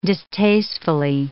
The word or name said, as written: distastefully